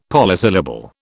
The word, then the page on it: polysyllable: British English